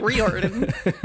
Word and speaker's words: Riordan: Rick Riordan. Is that? I'll just pronounce it different every time. – Riordan. Little Ricky.